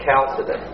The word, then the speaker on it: Chalcedon: Pronounced with a hard CH, so Chalcedon.